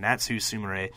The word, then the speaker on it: Natsu Sumire: Maya Yukihi and Natsu Sumire. Ooh, butchered that one. Natsu Sumire. Sumire. Maya and Natsu against Sayori Anno and Jungle Kiona, who seemed like quite a big deal.